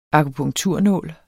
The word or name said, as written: akupunkturnål